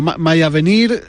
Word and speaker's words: Mayavenir: Mayavenir del FAN. Sí, lo dices bien porque en el fondo la primera palabra es en inglés. Y la segunda es en francés.